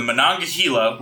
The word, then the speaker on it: Monongahela: Mananunga. – Malaklikilaka. – Fuck, I went through the goddamn pronunciation before the episode. The Monongahela. The 300 Pinkerton agents now stuck on the barges of the Monongahela, which killed 7 strikers and injured 11, with 2 dead and 12 injured for the Pinkertons in the end.